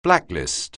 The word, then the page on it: blacklist: anglais